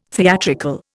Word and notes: theatrical: Indian

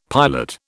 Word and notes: pilot: British English

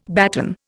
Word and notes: baton: Indian

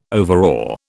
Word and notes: overawe: British English

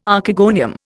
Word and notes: Archegonium: Indian

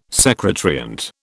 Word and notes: secant: British English

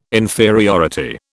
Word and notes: inferiority: British English

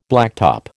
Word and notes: blacktop: American English

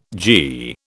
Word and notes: g: British English

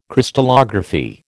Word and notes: Crystallography: American English

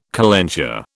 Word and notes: calenture: British English

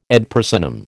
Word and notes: ad personam: American English

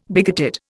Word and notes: bigoted: Indian

